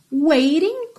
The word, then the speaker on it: waiting: Então, waiting com rarará no T, ok?